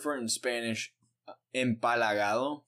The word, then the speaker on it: empalagado: How do you say that? – Empalagado.